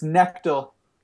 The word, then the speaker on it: Knechtel: It's look at the spelling. Knechtel. It's hard for anyone to pronounce. So it's Knechtel. – Ben Knechtel. – Yeah, like KN as in knife. You don't say knife.